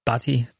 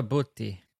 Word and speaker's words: Butti: How do you say that? B-U-T-T-I? – Batti, Booty. Roberto Booty.